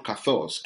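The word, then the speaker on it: kathos (καθώς): You see how it's spelled as, but it's the word kathos, K-A-T-H-O-S, kathos.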